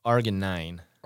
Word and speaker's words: arginine: So, I just looked up how to pronounce it. So, it's arginine.